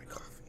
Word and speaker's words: Coffey: So if you're not familiar with coffee grain, it's spelled C-O-F-F-E-Y, and it's capitalized.